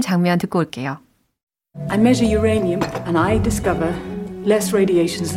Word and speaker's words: uranium: Yeah uranium.